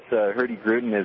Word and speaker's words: Hurtigruten: It's part of the, I can never pronounce it right, it's the Hurtigruten. Did I get that right? – Yeah, that's close. Hurtigruten is the company.